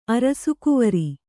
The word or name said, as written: ಅರಸುಕುವರಿ